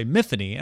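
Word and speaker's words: Myfanwy: Yeah, so I'm listening to the audiobook and I hear them say Miffany, and I'm like, Miffany. – Oh, okay, that's interesting. And then immediately in the book, there's a description of like, it's not, I don't pronounce it the traditional Welsh way. I pronounce it in a way that rhymes with Tiffany.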